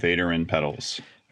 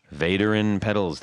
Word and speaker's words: Vaderin: Hi, Vaderin Pedals. – Vaderin Pedals, that's correct.